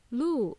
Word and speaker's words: loo: Or the more polite way to ask is, "Can you please tell me where the closest loo is around here?" Instead of toilets, you can use this word, loo. It's spelled as L-O-O.